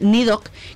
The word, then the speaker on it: Nidhögg: Los nombres raros, porque estábamos hablando aquí, este hombre y yo, del nombre de su propia banda, que es Nidok, que vosotros lo escucháis pronunciado, pero es que si lo habéis escrito ya os cagáis, porque... Este ride, de hecho, mira, voy a deletrearlo, es Nidhogg, por si alguien quiere buscarlos.